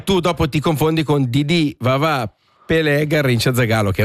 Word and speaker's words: Didi: Che noi chiamiamo Didi, ma in realtà la vera pronuncia è Gigi.